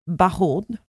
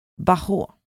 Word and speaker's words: Bahuaud: Okej, men då börjar jag med att säga paret François och Marie Bajon. Bajon? jag det direkt. Det Stavas B-A-H-U-A-U-D. Bahå. Vi säger det. Ja.